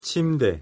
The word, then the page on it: 침대: Korean